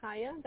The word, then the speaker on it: Chaya: My name is Kaya. That's spelled C-H-A-Y-A.